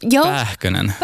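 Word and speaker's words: Pääkkönen: Pähkönen.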